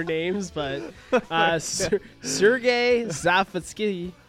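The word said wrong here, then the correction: Savitsky: What? – Savatsky? – Savatsky. – Yes.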